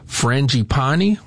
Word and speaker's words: frangipani: How can I get my, I had to even look this up, how to pronounce it, frangipani to frangipani. – Bloom? Frangipani. There you go.